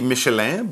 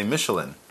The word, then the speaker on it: Michelin: In French, it would be Michelin, but in English, we just say Michelin.